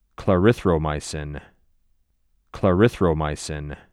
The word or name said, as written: Clarithromycin